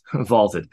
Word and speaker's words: VLTED: Vaulted. V-L-T-E-D. Pronounced vaulted.